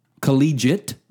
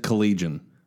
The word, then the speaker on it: collegiate: Collegiate? – Yeah. There's no N, right? – What did I say? – You said collegian.